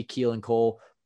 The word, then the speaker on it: Keelan Cole: I'd like to see Keelan Cole.